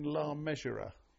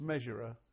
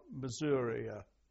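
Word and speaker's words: Le Mesurier: No one spells his name correctly, but it's Measure, M-E... S-U-R-I-E-R, and it's pronounced Measurer, not Missouria, Missouri, whatever.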